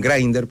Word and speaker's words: Grindr: Teniente, puede ser también que utilizan Grindr, porque así se pronuncia, nos acaban de corregir y le queremos agradecer. – ¿Cuánto es? – Grindr.